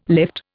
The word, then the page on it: lift: Indian